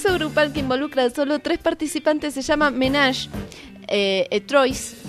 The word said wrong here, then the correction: ménage à trois: Ménage à trois. – Término francés que se pronuncia como lo dijo Daniel.